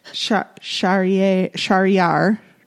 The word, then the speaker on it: Charrière: Because it was about a man who thought he was wrongfully convicted, a French prisoner, Henri Charrière. It's French, so it's hard to say. Charrière.